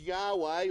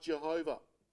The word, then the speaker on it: Yahweh: The most accepted pronunciation is Yahweh or Yahweh, but really no one knows. So it could just as well be something like Jehovah.